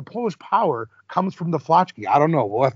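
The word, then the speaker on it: flaczki: I have an Polish soup called flotchki. Is that how you pronounce it correctly? – Flotchki? Oh, yeah. Flotchki. That means intestine.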